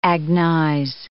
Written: agnize

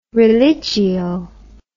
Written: religio

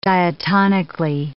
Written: diatonically

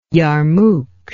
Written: yarmuk

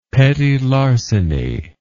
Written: petit larceny

